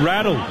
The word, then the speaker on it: Rattle: It's John Rattle. – Rattle? – Rattle like saddle.